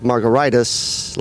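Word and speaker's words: Margaritis: Well, my last name is Margaritas, not Spanish. It ends in an IS. It should be pronounced like Margaritas, like hepatitis, gingivitis, laryngitis.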